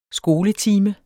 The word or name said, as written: skoletime